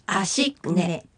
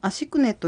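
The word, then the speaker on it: アシㇰネ: 次に 5 という意味のアシクネ、アシクネ。 – このアシクネという単語は小さなクというのが入ってきますので発音に注意しましょう。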